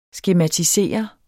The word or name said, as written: skematisere